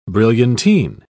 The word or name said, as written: brilliantine